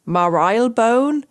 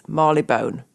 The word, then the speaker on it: Marylebone: It's not Marylebone, it's not Marylebone, it is Marylebone.